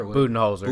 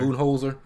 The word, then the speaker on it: Budenholzer: How do you pronounce his name? You know I'm bad with names. Budweiser? – Budenholzer. – Budenholzer.